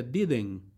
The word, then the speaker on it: didn't: La pronunciación de didn't es un poco nasal. Por eso no solo se dice didn't, sino que didn't, que suene entre nasal, didn't con la T al final.